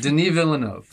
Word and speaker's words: Villeneuve: Is that how you pronounce it? Denis Villeneuve. – Is it Villeneuve? – Denis Villeneuve. – Thank you. – Denis is that what it is? Denis Villeneuve. – Yes, thank you.